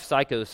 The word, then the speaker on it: Saiko: But he begins by saying this: my wife Saiko's family loves animals. And by the way, it's spelled S-A-I-K-O. I realize that sounds like psycho. Anyway, but it's not. It's not spelled the same way.